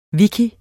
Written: wiki

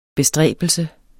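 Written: BESTRÆBELSERNES

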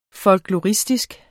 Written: folkloristisk

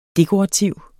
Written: dekorativ

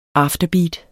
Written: afterbeat

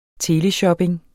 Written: teleshopping

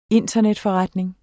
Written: internetforretning

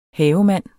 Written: havemand

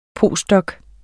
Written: postdoc

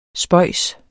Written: spøjs

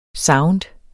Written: sound

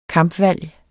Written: kampvalg

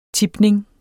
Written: tipning

